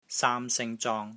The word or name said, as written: SAAM1 SING1 ZONG1